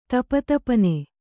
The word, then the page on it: ದಪದಪನೆ: dapadapane